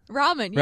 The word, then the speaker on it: ramen: Ramen. – Really? – Is that how you say it? – Ramen, yeah. – Ramen, yeah. Initially, when I looked at the words, I was like, Raymond?